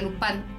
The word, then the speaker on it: Lupin: Bueno, en Netflix la pueden ver como Lupin, pero es, se pronuncia Lupin en francés.